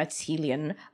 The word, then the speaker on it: Qilin: I'm Chinese myself, so I grew up calling it a Qilin, Q-I-L-I-N.